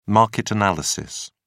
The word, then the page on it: market analysis: British English